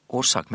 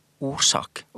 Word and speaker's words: orsak: men o-r-s-a-k. Og det blir da uttalt som årsak, men det skal uttales årsak, og det er et helt annet ord enn årsak.